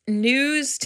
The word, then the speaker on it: gnu: So the clue was it's news to me, which is spelled news spelled G-N-U-S. Because it's actually pronounced new instead of gnu, but I think everybody says gnu.